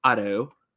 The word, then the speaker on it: Otto: Everyone, please welcome Matt Otto.